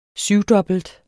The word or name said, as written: syvdobbelt